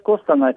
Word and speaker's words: Костанай: Ну, он по-старому Кустанай, но в основном уже пишется новое административное название Костанай. – Это я знаю. Я спрашиваю, как у вас говорят. – Ну, у нас говорят Костанай.